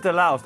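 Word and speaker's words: De-Loused: So so Skip's joining us to do the rankings and then also to get into the main album, which we're doing Delaust and the Comatorium. Is that even how you pronounce it? Is it Delaust? How do you pronounce it? – It's Delaust. I mean, I really hope that's the album we're doing because my notes otherwise are useless.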